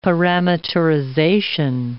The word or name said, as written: parameterization